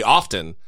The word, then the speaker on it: often: often, apparently they think that's mispronounced. They say, no, the T is silent.